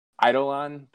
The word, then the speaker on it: Eidolon: But we've got Eidolon. Did I say it right that time? Hopefully. – You got it, dude.